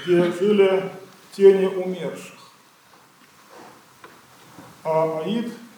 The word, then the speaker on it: Аид: Вечной, конечно, до тех пор, пока Христос не разрушил ее и не связал этого ада. Пишется это слово, там буква А, потом греческая «иота», но она подписная, и она не читается. Поэтому два произношения есть. Классическое «аид» и церковное «ад», без этой «иоты».